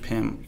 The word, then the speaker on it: Pym: It's pronounced Hank Pym.